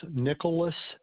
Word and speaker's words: Nickolas: Yes, my name is Nicholas Martin. Nicholas is spelled N-I-C-K-O-L-A-S. The last name is Martin, M-A-R-T-I-N.